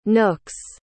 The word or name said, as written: nooks